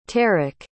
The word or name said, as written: tarek